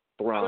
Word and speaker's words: Thrawn: Thrawn.